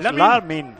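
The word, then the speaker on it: Lamine: Que no es tan difícil, Lamin. Que son dos sílabas. Lamin.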